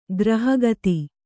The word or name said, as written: ಗೃಹಖಾತೆ